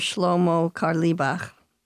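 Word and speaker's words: Shlomo Carlebach: You are the daughter of the famous rabbi and singer Shlomo Karlibach.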